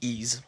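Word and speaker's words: Ys: Ease, en passant, on dit Ease. C'est comme ça qu'il se prononce.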